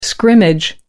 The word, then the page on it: scrimmage: American English